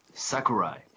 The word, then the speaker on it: Sakurai: Sakurai.